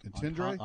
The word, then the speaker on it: entendre: Entendre? – Entendre.